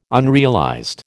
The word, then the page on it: unrealized: American English